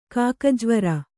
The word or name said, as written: ಕಾಕಜ್ವರ